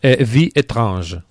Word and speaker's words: Vie Étrange: ¿Cómo se dice aquí? – Vie Étrange. – Eso es.